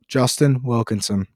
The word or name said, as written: Wilkinson